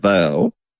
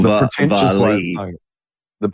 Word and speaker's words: vale: It's pronounced, it does say pronounced like veil with one syllable rhyming with pale.